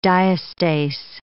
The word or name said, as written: diastase